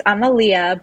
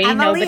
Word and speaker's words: Amalia: No. It's Amalia, but don't worry. Amalia. Nobody ever knows, how to say it. It's a very Greek, old school, traditional Greek name.